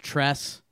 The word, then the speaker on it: Trace: His name is supposedly pronounced Tress, but it's spelled Trace.